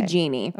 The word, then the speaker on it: Jeanne: Jean or Jeanne. I'm not sure how you say it. J-E-A-N-N-E. – Yeah. – Is it Jean or Jeanne? – Jeannie? – We'll say Jeannie.